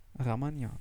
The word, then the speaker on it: Ramayana: Ramayana.